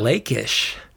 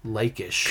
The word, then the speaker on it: Lachish: Lakish. Lakish. Because that CH makes a K sound.